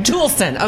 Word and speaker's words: Toolson: Toolson.